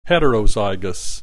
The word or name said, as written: heterozygous